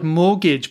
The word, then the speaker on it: mortgage: This word mortgage, by the way, is another one with a silent letter. T, we do not pronounce because it's a French word.